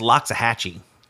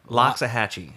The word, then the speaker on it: Loxahatchee: Joshua James, 23, pulled into the drive-thru at a Wendy's in Lexicachichi, Florida. – Lexicachichi? That sounds delicious. I want one of those. Oh, I think that's Loxahatchee. – Okay. – Loxahatchee, because I think that's how that's pronounced.